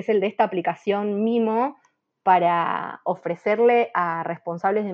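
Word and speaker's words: Meemo: La página es, se escribe distinto que cómo se pronuncia. Se dice mimo, pero se escribe Memo con doble E, M-E-E-M-O, punto vet es la página.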